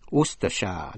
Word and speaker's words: Worcestershire: Worcestershire, đánh vần W-O-R-C-E-S-T-E-R-S-H-I-R-E, Worcestershire. Chữ Worcester, W-O-R-C-E-S-T-E-R, cũng là nhãn hiệu một thứ đồ sứ porcelain nổi tiếng làm ở vùng Worcester bên Anh.